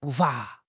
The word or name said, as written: 步伐